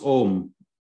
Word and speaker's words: Om: That's why you see sometimes car number plates with A-U-M written on it. So when you pronounce it, it's Om, A-U-M, and when you write it, it's O-M.